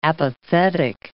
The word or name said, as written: apathetic